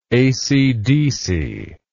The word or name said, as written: acdc